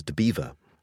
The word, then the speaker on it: De Beauvoir: Well, the bit of London that I live in is called De Beauvoir, but apparently the local pronunciation was De Beaver.